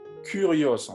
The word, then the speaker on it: Kyrios: Kyria, c'est la forme féminine de Kyrios, qu'on prononce généralement Curios en français, et qui veut dire Seigneur.